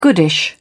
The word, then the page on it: goodish: British English